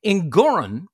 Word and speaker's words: Engoron: Engoron, which rhymes with moron, didn't care.